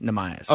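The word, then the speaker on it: Neemias: So I think Nemias is it's either Nemias or Nemi. I can't. – I believe it's. I believe that Monty said it's pronounced Nemias.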